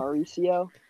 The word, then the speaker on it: Mauricio: How do you say his last name? – Ronnie Mauricio. – Mauricio. Yeah.